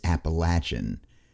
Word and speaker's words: Appalachian: Yes, I know the quote-unquote real pronunciation is Appalachian, but an Appalachian appellation just isn't funny.